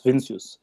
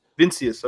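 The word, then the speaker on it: Vinícius: Is it Vinicus from Brazil that they brought in? Like, is that Vincius? – Vincius. Vincius. Okay.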